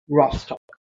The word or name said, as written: Rostock